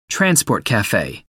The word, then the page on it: transport cafe: American English